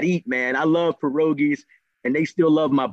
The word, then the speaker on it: pierogi: I do know what it, well, we call them pierogies. We pronounce the R as a D, but come yes, I know exactly what they are.